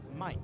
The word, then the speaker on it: Maick: Spells it M-A-I-C-K, pronounced Mike.